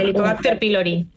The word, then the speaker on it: Helicobacter pylori: Helicobacter pylori.